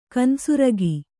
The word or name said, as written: ಕಾನು ಸುರಗಿ